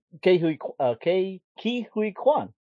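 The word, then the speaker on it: Ke Huy Quan: A lot of love for Ke-Huy Quan.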